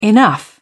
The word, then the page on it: enough: British English